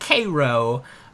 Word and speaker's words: Cairo: It's spelled Cairo, but it's pronounced Cairo.